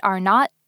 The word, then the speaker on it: R0: It's written as R0 and pronounced R0.